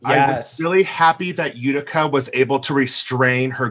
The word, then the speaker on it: Utica: What did you think of the winners of this week, which was am I gonna fuck up the name Utica, and did I say it right, Robbie? – That yeah, sounded okay, out.